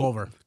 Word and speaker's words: Culver: February 29th, I'm going to be at T-Mobile First in Clover City on Jefferson. – Wait. – Did I say that right? – Nope. Culver.